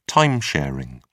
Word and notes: time sharing: British English

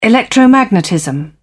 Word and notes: electromagnetism: British English

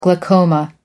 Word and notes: glaucoma: American English

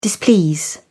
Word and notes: displease: British English

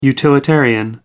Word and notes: utilitarian: American English

